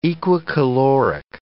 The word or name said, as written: equicaloric